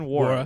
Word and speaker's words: Nwora: How do you say it? Jordan Nwora, right? – Yeah. – I don't know why I included the N.